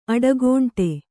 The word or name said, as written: ಅಡಿಗಂಟು